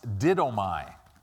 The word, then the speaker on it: didomai: This word gives is the Greek word that is pronounced didomai.